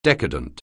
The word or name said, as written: decadent